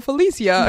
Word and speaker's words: Felicia: Me llamo Felicia, because that's how you always pronounce my stupid name in Spanish, which pisses me off.